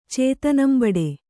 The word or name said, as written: ಚೇತನಂಬಡೆ